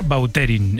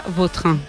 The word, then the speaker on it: Vautrin: Es que a mí me suena mejor Bauterín. Es como... – Da más fuerza, ¿no? – Más fuerza, más pronunciación. – Sí. Elegante. – La original francesa, Vautrin.